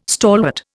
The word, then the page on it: stalwart: Indian